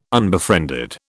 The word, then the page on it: unbefriended: British English